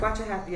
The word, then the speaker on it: Waxahachie: Waxahachie, Texas? – That is correct. Waxahachie, yes. – So, finally. Waxahachie.